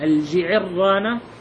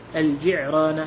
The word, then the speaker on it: الجعرانة: قال بعد ذلك واعتمر رسول الله صلى الله عليه وسلم من الجعرانة، طبعا الجعرانة تنطق يعني بلفظين، الجعرانة والجعرانة، الجعرانة هكذا.